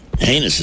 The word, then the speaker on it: heinous: I thought she meant to say heinous, but heinous is the correct pronunciation of H-E-I-N-O-U-S, correct? – I would say.